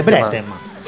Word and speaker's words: Brétema: Bretema o algo así. Así, tal cual, se dice Bretema.